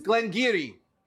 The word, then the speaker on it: Garioch: It's Glengarry. Of course. G-A-R-I-O-C-H. And it means the Valley of the Granary.